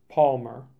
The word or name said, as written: Palmer